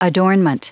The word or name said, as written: adornment